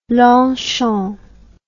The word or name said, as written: longchamp